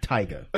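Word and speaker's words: Tyga: Tigger. – You had it right, and then you were starting to fuck it up again. It's Tiger. – You had it right. Were you gonna say Tiger? – Yeah.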